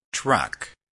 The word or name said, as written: truck